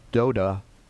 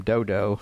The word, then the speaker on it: Doda: It says, "Now, after Abimelech died, Tola, the son of Puah, the son of Doda," and by the way, Doda is the correct pronunciation. I checked on it. He didn't go through life with the name Dodo, although it's hard to read that and not say that. But so, after Abimelech died, Tola, the son of Puah, the son of Doda, a man of Issachar, arose to save Israel.